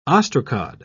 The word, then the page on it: ostracod: British English